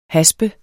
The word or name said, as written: haspe